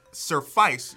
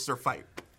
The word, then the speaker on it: surfeit: Which would make you think surfice, but it's Sir Fight. – Give it to me, Maya. – Not quite. – Yes. – Surfeit.